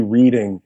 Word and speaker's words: Reading: One time I did a show in Reading, Pennsylvania, and I said, "Thank you, Reading," because it's spelled R-E-A-D. And they were like, "Reading."